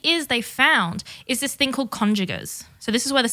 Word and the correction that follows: congeners: It's actually congeners, by the way. That's how it's pronounced.